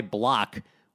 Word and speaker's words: Blach: He spotted the Orioles a 6 0 lead, matter of fact, but then the Orioles completely imploded, starting with their starter Ty Blach which is actually spelled like somebody vomiting, which is appropriate. It's B L A C H, like black.